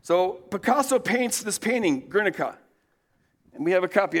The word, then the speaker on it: Guernica: It's pronounced, I looked it up, Guernica.